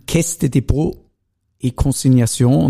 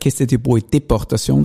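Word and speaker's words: Caisse des Dépôts et Consignations: Und äh, CDC, kannst du das mal im Ganzen aussprechen auf Französisch, bitte? – Caisse de Depot E-Consignation und irgendwer hat einmal gesagt, Käste de Boe Deportation, das ist es nicht ganz gewesen.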